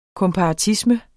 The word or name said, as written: komparatisme